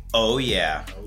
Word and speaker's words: Oouyeah: Oouyeah. Am I pronouncing that correctly? – Oouyeah, that's right.